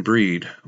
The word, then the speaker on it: Brigid: That was Welcome Breed, Breed being the Irish pronunciation of Brigid.